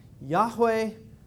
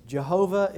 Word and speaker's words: Yahweh: Yahweh, Jehovah, if you want to be really wrong about how to pronounce the word. You say it Yahweh, I say it my way. But the one thing we can be dogmatic about is it not Jehovah.